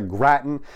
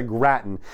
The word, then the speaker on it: Gratton: It's Tessa Gratton, not Gritton. My apologies on the pronunciation. I was putting a short I instead of a short A in there for some reason. So Tessa Gratton there you go.